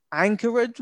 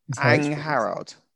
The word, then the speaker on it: Angharad: And this is where I mispronounce things again. Angharad Reese. – Angharad. – Angharad. Yeah. Sorry.